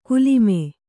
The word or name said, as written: ಕುಲಿಮೆ